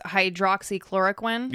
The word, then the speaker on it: hydroxychloroquine: Yeah, so I think the way you pronounce this drug is hydroxychloroquine. – Yeah, that's right.